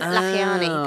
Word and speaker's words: Lakhiani: Yeah, Vishen Lakhiani.